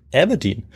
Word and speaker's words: Aberdeen: Der lässt daraufhin nicht lange auf sich warten und führt seine Truppen unverzüglich in den Norden nach Aberdeen, an die Ostküste Schottlands. Und das habe ich deshalb so schön ausgesprochen, weil du die Stadt ja auch gut kennst. – Ja, du hast es auch richtig ausgesprochen.